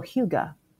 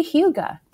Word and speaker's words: hygge: hygge. – Do you mean eat a little more Danish? Because I would like to do that. – That would be very hygge.